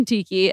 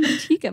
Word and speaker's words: tikka: Tikka masala? I think it's tikka. – Yeah. – I always say chicken tiki and I know that's wrong every single time.